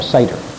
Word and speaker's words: Seder: That E in S-E-D-E-R is pronounced as a long A, like you say. Say, S-A-Y, say, Seder.